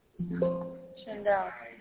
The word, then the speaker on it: Shaindel: Shindel. – Shindel, how do you spell that? – S-H-A-I-N-D-E-L.